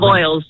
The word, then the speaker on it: Avoyelles: Well, you connected us to the place that we're calling home right now in Avoyelles, Louisiana. Did I say that right? Maybe I got that Avoyelles. – Right. Avoyelles.